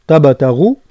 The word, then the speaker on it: تبتغوا: ثم حذاري ننتبه إلى نطق تبتغوا، منفخموش التاء تصبح طاء تبتغوا، والباء انقلق لها يعني تبتا يعني التاء الأولى، والباء والتاء الثانية رقهم مع قلقلة الباء، ثم الغين مفخمة مع بيان المد تبتغوا هكذا، مش تبتغوا، تبتغوا فضلا من ربكم، يعني فضلا ترقيق الفاء وتفخيم الضاد، فضلا من ربكم.